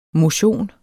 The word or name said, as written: motion